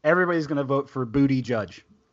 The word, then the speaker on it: Buttigieg: It is John McAfee. It is Amy Klobuchar. It is Pete Buttigieg. I swear to God, it's Pete Buttigieg.